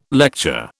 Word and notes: lecture: British English

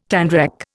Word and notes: tanrec: Indian

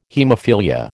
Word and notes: haemophilia: American English

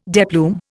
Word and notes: deplume: Indian

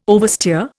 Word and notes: oversteer: Indian

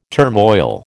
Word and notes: turmoil: American English

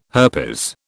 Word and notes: herpes: British English

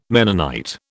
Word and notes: Mennonite: British English

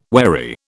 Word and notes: wherry: British English

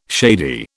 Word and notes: shady: British English

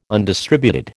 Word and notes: undistributed: American English

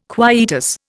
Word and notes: quietus: Indian

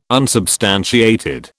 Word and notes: unsubstantiated: British English

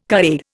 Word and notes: carer: Indian

